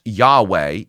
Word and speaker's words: Yahweh: In English, it would be YHWH, and it's often pronounced Yahweh, even though we're not really certain about that pronunciation because the ancient Jews wouldn't actually speak the name of God.